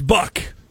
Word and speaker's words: Buck: It's Buck. B-U-C-K.